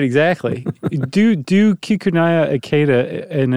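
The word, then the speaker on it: Kikunae: So here's a gentleman that comes along in 1909, and his name is Kakune Akita. Is that right? – Not bad. – What'd I get wrong? – There's like an extra little half syllable in there. Kikunaya.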